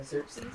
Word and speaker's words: Xerxes: Xerxes.